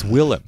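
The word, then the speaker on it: Willem Dafoe: It's Willem. just take away a syllable.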